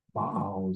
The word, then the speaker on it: Baal: Baal is actually the way it's pronounced in this place, the very names of the idolatrous priests.